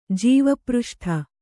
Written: ಜೀವಪೃಷ್ಠ

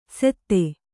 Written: ಸೆತ್ತೆ

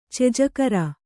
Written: ಚೆಜಕರ

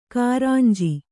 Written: ಕುರಂಜಿ

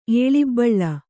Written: ಏಳಿಬ್ಬಳ್ಳ

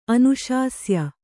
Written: ಅನುಶಾಸ್ಯ

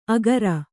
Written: ಅಗರ

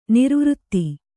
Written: ನಿರ್ವೃತ

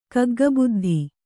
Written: ಕಗ್ಗಬುದ್ಧಿ

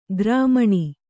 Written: ಕಾರ್ಮಣಿ

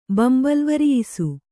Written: ಬಂಬಲ್ವರಿಯಿಸು

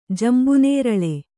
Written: ಜಂಬುನೇರಳೆ

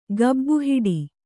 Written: ಗಬ್ಬುಹೊಡೆ

